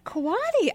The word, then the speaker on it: Quade: And one thing I'll say right off the bat is my name looks like Quaid, but it's actually pronounced Quaddy. – Quaddy.